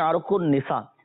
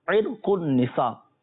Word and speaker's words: عرق النساء: لوگ بولتے ہیں عرق النساء، صحیح تلفظ ناظرین اس کا عرق النساء،